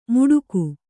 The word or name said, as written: ಮುಡುಗು